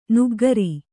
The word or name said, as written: ನಗೆಗಾರ